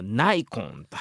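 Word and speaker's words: Nikon: そして歌詞の中にですねニコンのカメラが登場するんですけれども、サイモンはナイコンと発音しているのが面白いところかなと思います。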